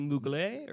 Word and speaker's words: Mugler: I don't think that's how you pronounce it. Muggler. Is it Mugler or like, yeah, okay.